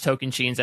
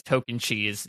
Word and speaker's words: Tocantins: an inside joke that we had was she would constantly purposely mispronounce Token Chains as Token Cheese just to get under my skin, and she succeeded in that regard.